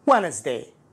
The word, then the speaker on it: Wednesday: Wednesday Wednesday الدي لا ينطق